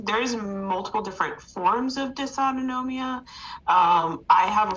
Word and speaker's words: dysautonomia: Dysautonomia, adoba. What did you say? – Dysautonomia. – Like onomatopoeia, but spell it for me. Dysautonomia. So it's dysautonomia. – Yes, yeah. Dysautonomia. – D-Y-S-A-U-T-O-N-O-M-I-A.